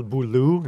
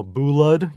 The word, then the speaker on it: Boulud: There's a pronunciation guide, so you know it's Danielle Boulou instead of Danielle Boulud, you know, that sort of thing.